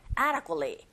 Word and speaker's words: adequately: 이렇게 발음하셔야 되는데, 첫 번째 강세를 꽝 주시고, ada, 여기는 d 사운드가 모음 사이에서 르처럼 들려서 ada, ada. 그리고 adequately가 아니라 역시 t 사운드가 거의 안 들리죠.